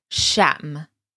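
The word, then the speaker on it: Sham (شمع): And that's a kind of difficult word to say. As you can see, I'm pronouncing it with a kind of long a sound. So Sham.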